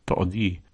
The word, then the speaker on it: tood: And car, or a car, or the car, there are no definite or indefinite articles in Volapük, is tood, that's T-O-O-D, but you pronounce each vowel separately, as I've mentioned before.